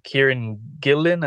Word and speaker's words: Kieran Gillen: This is a new X title, basically written by Kieran Gillen. I think I'm saying his name correctly. – That's it. That's it.